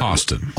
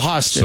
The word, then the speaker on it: Hostin: I don't even know how do you say her name? – Hostin. – Oh, Hostin.